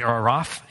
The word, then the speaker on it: Araf: There's a wall between heaven and hell, and people who are there are, it's called the Araf, A-R-A-F is how they pronounce it or spell it in English.